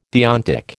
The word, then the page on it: deontic: American English